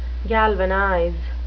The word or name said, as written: galvanize